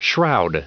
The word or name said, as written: shroud